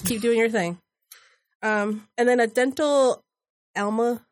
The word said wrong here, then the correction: amalgam: I don't know how to say it. – Amalgam. – Yeah. Amalgam. Yes. Thank you.